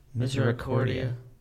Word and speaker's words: Misericordia: Misericordia.